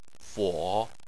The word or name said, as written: fo3